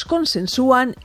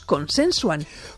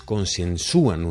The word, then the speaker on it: consensúan: Por lo que contáis, debe ser ellos consensúan y no ellos consensuan. – Hola. Sí, por poner un ejemplo, se puede decir que cierto número de partidos consensúan una medida. Es decir, se pronuncia con la U tónica y se escribe con tilde en los mismos casos que el verbo actuar, que sirve aquí como modelo.